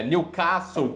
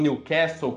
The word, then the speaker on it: Newcastle: Na, no saber falar é, o nome de um time certo, por exemplo, é, Newcastle, que é como eles pronunciam na Inglaterra, e não Newcastle, como é um outro sotaque.